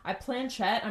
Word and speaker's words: planchette: Yeah, you ask questions and the spirit answers by moving the, what'd you say, pequette, whatever it's called. – Planchette. I'm planchette, pretty sure that's how you say it.